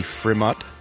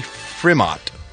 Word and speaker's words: Frimodt: Sean William Scott got engaged to his girlfriend, Lindsay Frimott. – What's her name? – Lindsay Frimott. – Okay. – I think that's how you say it. F-R-I-M-O-D-T. Frimott? – Yeah.